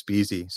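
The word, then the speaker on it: Beese: Yeah, the good news of having the name Vince Beasy is that there's only one Vince Beasy.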